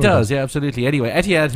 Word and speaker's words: Etihad: Etihad? – No, Etihad is fine.